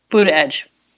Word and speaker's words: Buttigieg: Buttigieg? – Buddha Edge.